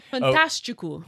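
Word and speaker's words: Fantástico: not to mention the Brazilian late night TV host on a show called Fantastico. Is that how I'm saying it? Am I saying it right? – Yeah. – Oh, good. – I remember that. Fantastico.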